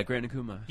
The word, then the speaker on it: Grand Akuma: Am I saying it right? Grand Akuma? – Yeah, Grand Akuma.